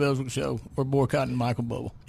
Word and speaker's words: Bublé: It's a joke now that he's saying Bubble. Understand, he understands that his name is Bublé, okay, yeah. But it's spelled Bubble. – In Arkansas, it's pronounced Bubble. – All right. – In Canada, it's Bublé.